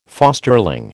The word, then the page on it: fosterling: American English